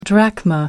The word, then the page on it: drachma: American English